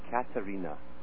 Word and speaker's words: Katerina: This one by Cherkov, he actually called it Katerina. That's what he called it when he first talked about it. – Oh, Katerina. – And, of course, that's the Russian pronunciation.